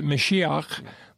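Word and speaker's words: Mashiach (מָשִׁיחַ): The Hebrew is Messiah, or, they would pronounce it Mashiach, but it's Messiah.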